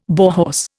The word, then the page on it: Bauhaus: Indian